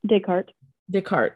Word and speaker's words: Descartes: Descartes.